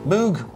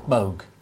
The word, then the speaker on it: Moog: Key thing, Moog, Moog. – Well, the Americans say Moog, the Dutch say Moog, and we didn't really know how to pronounce it when the instruments first arrived, so we called them Moogs.